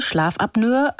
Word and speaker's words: Schlafapnoe: Hier ganz kurz der Hinweis, das ist tatsächlich die korrekte Aussprache, Schlafapnoe. Viele Menschen, übrigens auch Ärzte, sprechen aber auch von Schlafapnoe.